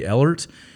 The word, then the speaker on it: Ehlert: If you have not subscribed to my YouTube channel, you can find me at Barry Ehlert. My last name is spelled E-H-L-E-R-T.